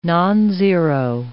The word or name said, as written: nonzero